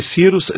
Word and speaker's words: Scirus: Então esse Cirrus se escreve assim, S-C-I-R-U-S.